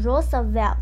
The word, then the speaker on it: Roosevelt: 不过呢，有一些字呢，它就是比较特别的一些发音，比如说呜呜可能发呜，偶尔发哦，像这个就是念成 Roosevelt，所以呢，就是有一些比较特别的发音喽。